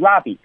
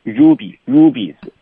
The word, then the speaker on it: rubies: 哎，大家注意哈，这个词呢不要读成 ruby 啊，那个 u 呢发成 u 的音，所以是 rubies，对吧？